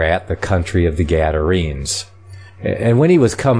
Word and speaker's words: Gadarenes: I kind of wonder about that name, Gadarenes. Gadarenos. That's the pronunciation I get from the Greek there.